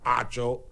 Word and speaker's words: Acho: And again, I've been mispronouncing Emmanuel Ocho's name wrong. Apparently, it's Emmanuel Acho.